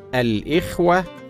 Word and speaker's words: الإخوة: من الكلمات المستخدمه في النداء قول المتحدث ايها الاخوه والاخوات هكذا تنطق الاخوه والاخوات بكسر الهمزه مع جمع التكسير المذكر الاخوه وفتحها مع الجمع بالالف والتاء الاخوات ولكن البعض ينطقهما بغير ذلك وهذا خطا شائع فالصواب ايها الاخوه والاخوات